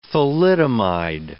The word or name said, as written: thalidomide